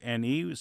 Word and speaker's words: Marchione: It's Marchione. Some may pronounce it Marchione, some just Marchione. – Yeah, in Italian it would be Marchione, mm-hmm. but the family in Pennsylvania says Marchione, so I've chosen to sort of accept that pronunciation.